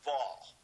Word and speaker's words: Vav: which in Hebrew I think is pronounced Vav.